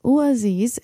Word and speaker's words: Oezys: Up next, let's play this band, which is called Ooaziz. Ooaziz, it is spelled like O-E-Z-Y-S, is a gloom metal band from Cincinnati, Ohio.